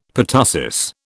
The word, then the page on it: pertussis: British English